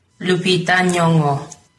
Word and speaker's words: Lupita Nyong'o: Lupita Nyong'o. In American, Lupita Nyong'o. Lupita Nyong'o. Lupita Nyong'o. Lupita Nyong'o. – Okay, Lupita Nyong'o. – The G is a soft G, so it's like Lupita Nyong'o.